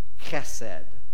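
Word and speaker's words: chesed (חסד): But this word that they are translating is a Hebrew word, chesed. You know, kind of like spit from your throat a little bit.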